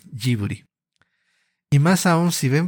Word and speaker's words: Ghibli: En japonés, en lugar de que el estudio se se, llame Giburi, que sería lo más cercano a la palabra original, Miyazaki lo nombra Jiburi.